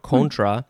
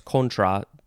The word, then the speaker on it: contra: And this can be pronounced a few ways. Contra, contra, if you want to give that long O sound, contra. This one is a noun from 1981, and it is a member of a guerrilla group opposed to the Sandinista government in Nicaragua in the 1980s. So this is highly specific. It is an American Spanish word. That's why it's contra. A little bit more accurate pronunciation there.